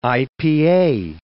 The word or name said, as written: IPA